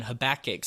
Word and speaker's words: Habakkuk: So first, let me ask you, did I say that right? – Absolutely. – Okay. – Now, I know your wife says it's Habakkuk or something. Poor Katie. – Yeah, so we just need to be clear here on the front end. How do you correctly pronounce it? – Habakkuk. – Thank you. Very good.